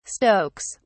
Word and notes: stokes: British English

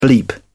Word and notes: bleep: British English

